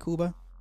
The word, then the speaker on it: Cuba: Cuba, right? That's how they say it, Cuba.